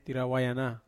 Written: awayana

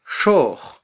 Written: xoj

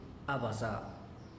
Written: abasa

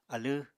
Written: alẃ

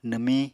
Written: nwmiñ

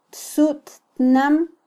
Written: tsuttnam

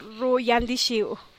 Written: rioang dixuuh

